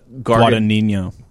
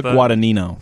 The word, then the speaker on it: Guadagnino: This one, directed by, and I'm going to butcher the name here, Luca Guadagnino. – Guadagnino, yeah. – Guadalupe. – Luca Guadagnino.